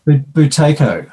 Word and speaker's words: Buteyko: The Buteyko method? How do you say that? – That was a... – How's it pronounced? – Buteyko. – Oh, I guess I'm getting close. – You're pretty close, yeah.